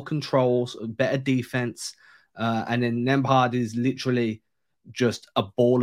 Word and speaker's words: Nembhard: And then, obviously, we had that big breakout from second-round draft pick Andrew, and I always butcher his name, Nembhard. – Yep, Nembhard.